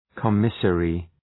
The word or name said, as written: commissary